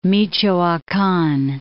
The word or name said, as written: Michoacan